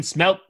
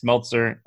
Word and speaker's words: Smeltzer: Um, we got Shane Bieber and Devin Smelt Smeltzer. I always say his name wrong, but I think it's Smeltzer.